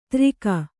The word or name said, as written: ದಾರಿಗ